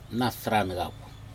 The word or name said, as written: nadrag cap